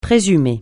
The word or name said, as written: présumer